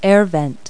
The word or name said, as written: air vent